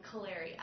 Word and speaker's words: Kaleri: It's called the Kaleri app. – Say it again? – Kaleri app. – How do you spell that? – K-A-L-E-R-I. – K-A-L-E-R-I.